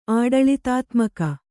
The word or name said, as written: ಆಡಳಿತಾತ್ಮಕ